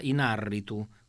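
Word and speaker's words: Iñárritu: È scritto con l'accento sull'A, è scritto in A con l'accento sull'A, Ignarritu. Quindi ho conosciuto la voce di Giuliani quando doppiò Scott Bakula in Quantum Leap.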